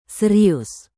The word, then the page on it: serius: Indonesian